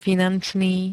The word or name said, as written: finančný